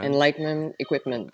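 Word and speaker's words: Enlightened Equipment: Comme euh, la Enlight euh, Equipment. – Enlightenment Equipment. – Enlightenment, oui. – Il manque une syllabe.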